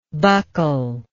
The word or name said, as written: buckleless